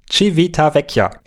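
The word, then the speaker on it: Civitavecchia: Am nächsten Tag sind wir in, oh oh, jetzt kommt's wieder, Leute, ich muss es aussprechen, Civita Vecchia. Nein, ist falsch, ne? Wie heißt, sag nochmal. Civita Vecchia. Am nächsten Tag sind wir dann in Civita Vecchia.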